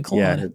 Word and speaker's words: Claude: My go-to now is Cloud, however you want to pronounce Claude? – It. – C-L-A. I would say Claude. – Yeah, one of those. C-L-A-U-D-E. by Anthropic.